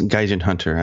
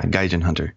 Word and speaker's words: Gaijinhunter: Is that how you say it? Gaijin? I'm really bad with words here. – Yes, Gaijinhunter. I know some people, I hand themselves Gaijinhunter and it just kills me. But yeah, Gaijinhunter.